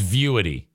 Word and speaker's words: Vuity: It's Vuity. It's spelled V-U-I-T-Y, and I pronounced it Vuity.